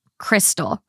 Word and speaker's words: Krxstxl: It's Crystal. K-R-X-S-T-X-L. So X's instead of Y's. – Yeah. – She wants to name her daughter Crystal. Confused, the name is pronounced Crystal.